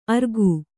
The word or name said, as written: ಅರಗು